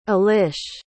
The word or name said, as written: alisz